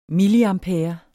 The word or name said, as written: milliampere